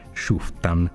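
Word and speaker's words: Schüfftan: conosciuta come effetto Schuttentan scusate, Schuftan, la prima pronuncia non è,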